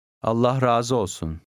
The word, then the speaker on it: Allah razı olsun: The A in the second word is long, the undotted I disappears, and the last two words run together. – Allah razı olsun.